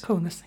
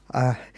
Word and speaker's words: Kaunas: One is in was a Vilnius-based team, and the other was I don't know if I'm saying this right, Kaunas. – Kaunas. Yes. – Kaunas.